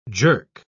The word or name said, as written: jerk